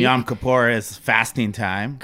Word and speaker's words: Yom Kippur: So, happy Yom Kippur.